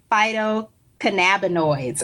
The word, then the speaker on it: phytocannabinoids: And now with CV Sciences and working with phytocannabinoids.